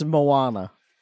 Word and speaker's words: Moana: Or Mona. I think it's, I don't know what, I still don't know. I saw the movie. I still don't know how you say it properly. – It's Moana.